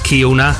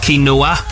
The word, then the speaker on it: quinoa: It's mispronounced Kiona. No, quinoa.